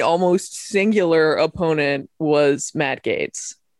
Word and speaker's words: Gaetz: Isn't it Gaetz? – I don't know. – I mean, do we care? – We don't care. – I think I'm pretty sure it's Gaetz.